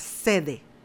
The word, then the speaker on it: ZEDE: ¿ustedes han escuchado de lo que es la sede? Z-E-D-E. debe ser la pronunciación correcta.